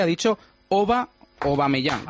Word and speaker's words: Aubameyang: Aubameyang, oh, pero es O. ¡Oh! – Aubameyang. – Auba, pero él ha dicho que le llamen Auba. Sí. – Hace unos días dijimos que se pronunciaba con la O.